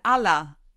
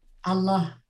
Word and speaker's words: Allah: Jetzt bin ich gerade drauf gekommen, ich habe es falsch ausgesprochen. Sie betonen es auf dem, auf dem L, Allah. Allah. Allah.